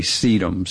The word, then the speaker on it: sedum: And one of my favorite ones is one of the many sedums. Sedums is a huge... – Spell that for me, would you? – S-E-D-U-M, like dumb.